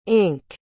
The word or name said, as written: inc